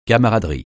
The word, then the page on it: camaraderie: francais